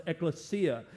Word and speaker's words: ekklesia: The original Greek word is a word that's pronounced ekklesia, and it means a gathering, an assembly, and it's the basis for our English word congregation.